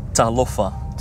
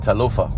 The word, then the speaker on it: Talofa: But Talofa means hello in Psalm 1. So if you ever see me, just say Talofa and... – Talo? – Talofa. – Talofa, yeah.